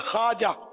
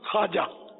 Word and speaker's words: خواجہ: خواجہ لفظ فارسی ہے, عربی نہیں ہے, اور اس کا تلفظ ہے خواجہ, واو بولا نہیں تھا خواجہ.